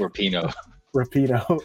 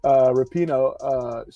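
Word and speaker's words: Rapinoe: As you know, I said on earlier podcast, you know, Megan Rampone. I always mispronounce the last name. – Rapino. – She's gonna hate me if she ever sees this. Rapino.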